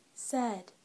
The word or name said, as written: said